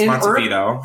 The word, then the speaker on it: Montevideo: It's Montevideo.